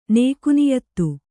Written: ನೇಕುನಿಯತ್ತು

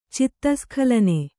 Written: ಚಿತ್ತಸ್ಖಲನೆ

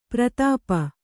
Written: ಪರಿತಾಪ

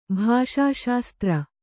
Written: ಭಾಷಾಶಾಸ್ತ್ರ

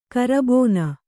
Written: ಕರಬೋನ

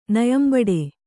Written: ನಯಂಬಡೆ

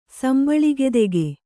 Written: ಸಂಬಳಿಗೆದೆಗೆ

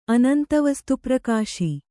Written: ಅನಂತವಸ್ತುಪ್ರಕಾಶಿ

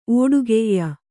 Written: ಓಡುಗೆಯ್ಯ

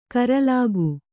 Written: ಕೂರಲಗು

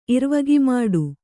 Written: ಇರ್ವಗಿಮಾಡು

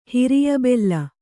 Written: ಹಿರಿಯಬೆಲ್ಲ

